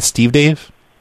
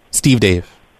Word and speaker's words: SteveDave: My name is Steve Dave. – Steve? – Steve Dave. – How do you spell that, please? – S-T-E-V-E-D-A-V-E. It's all one word. Steve Dave. It's like Steve and Dave.